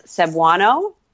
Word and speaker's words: Cebuano: And she speaks both like Tagalog and, I'm going to butcher the pronunciation of this, Cebuano? – That sounds good to me.